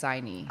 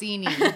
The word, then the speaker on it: Inee: No, it's Ini. It was Ini. – Ini? – Yes. – Okay, it's spelled I-N-E-E, so I wasn't sure how to pronounce that first I. But in... What did you just say it was? – Ini. – Ini, thank you.